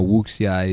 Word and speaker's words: Wuxia: Wuxia. – That is the proper pronunciation. – Up until that point, this film was... – Of that fighting style, right? – Yeah. – So for the listeners now, Wuxia is a...